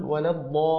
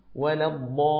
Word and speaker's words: ولا الضالين: لا تقف على اللام قليلا، فتقول ولا الضالين، هذا خطأ، فإن حرف اللام ليس من الحروف التي فيها غنة حتى تقف عليه غنة بمقدار حركتين، فتشديده يأتي في نطقه، فقل ولا الضالين هكذا، فلتتفضل يا أستاذ صالح.